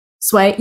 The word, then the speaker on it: Sway: My friends always try to say my name and my friends would be like, oh, hey, Sway. And I'll be like, nope, nope, nope, nope, nope. Sway. Yeah, that's right.